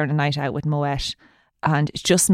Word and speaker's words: Moët: It's pronounced Moët, by the way. That's the night I learned how to pronounce Moët. To be fair, it did teach me that life lesson.